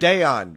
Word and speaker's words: Dayon: Is it Aon? – It's Dayon, Rick. – Dayon, with a D.